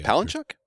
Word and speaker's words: Palahniuk: Chuck Palahniuk's, I hope I'm saying his name correctly. – Palahniuk? – Palahniuk. – Yeah, I think that's P-A-L-A-H-N-I-U-K.